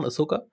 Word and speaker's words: Ahsoka: Ahsoka heißt das, wird so ausgesprochen, Ahsoka? – Ja.